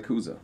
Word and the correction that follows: Kuzo: Scotty Kuzo. – Yeah. Scotty Kuzo. Right, Kuzo. Cool. – Yep. Excellent. – Yep. Kuzo. Yep. If you want to say it correctly.